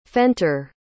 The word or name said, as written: fenter